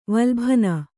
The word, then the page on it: ವಲ್ಭನ: valbhana